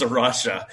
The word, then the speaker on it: A Rocha: So I may not even be pronouncing it correctly, but how we've come to understand to pronounce it is Arusha.